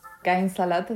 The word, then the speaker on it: Kein Salat: Kein Salat, la, la pronunciación correcta. Es una frase en alemán.